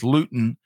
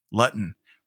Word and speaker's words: Luton: Uh, as I like to tell folks, my last name is Luton, but it's been pronounced at least on the first day of school for my entire life, Lutton right?